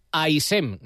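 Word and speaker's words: Haissem: AISEM, nada de la hacha aspirada ni nada, AISEM, así lo pronuncian en Villarreal, aunque aquí como es Josín, pues no hay problema porque ese es el nombre con el que le ha bautizado la afición.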